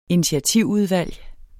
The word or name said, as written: initiativudvalg